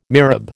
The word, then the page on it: mihrab: American English